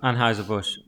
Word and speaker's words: Anheuser-Busch: Anheuser-Busch.